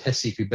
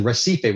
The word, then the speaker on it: Recife: Brazilians pronounce it Recife, but for argument's sake, I'm going to go with kind of the Americanized or English version, Recife, which is Northeast Brazil.